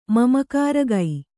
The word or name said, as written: ಮಮಕಾರಗೈ